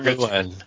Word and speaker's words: Llewyn: You got to get the name right. – Inside Llewyn. – There you go, because it's Welsh.